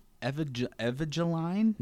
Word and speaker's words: Evangeline: Evangeline? – Yeah, sounds good.